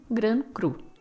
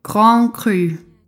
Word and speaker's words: Grand Cru: Eu sou incapaz de pronunciar o R francês. E vai ter um monte de R francês no programa de hoje. Então, eu vou falar a portuguesado mesmo, gran cru. Chega a me doer nas cordas vocais tentar falar o francês. Mas só para constar, a pronúncia correta então é Grand Cru.